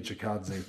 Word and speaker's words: Chikadze: I know you fixed it later on, but it's like the Giga Chikadze thing. Did I say it wrong again? – I think just did. Yeah, Giga Chikadze. Yes, there you go.